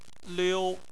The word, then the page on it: liu: 1